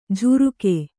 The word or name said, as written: ಜುರುಕೆ